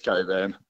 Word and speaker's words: Cockburn: How do you call it? Coburn. – It's Coburn. – What, but it's spelt Cockburn, isn't it?